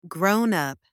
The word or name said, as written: grown-up